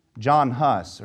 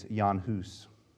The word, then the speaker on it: Jan Hus: John Huss, or if you want to pronounce it accurately, it's Jan Hus, was set on fire while singing hymns to his last breath.